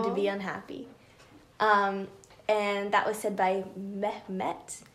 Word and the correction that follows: Mehmet: I don't know why I felt the need to pronounce the H there. Mehmet.